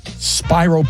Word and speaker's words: Spiro: The town of Spiro, Oklahoma, spelled like Spiro, S-P-I-R-O, but it's pronounced Spiro.